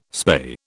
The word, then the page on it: spay: British English